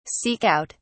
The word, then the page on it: seek out: British English